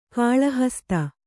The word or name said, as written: ಗಳಹಸ್ತ